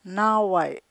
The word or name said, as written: naaway'